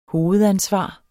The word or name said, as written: hovedansvar